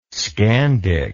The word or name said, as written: Scandic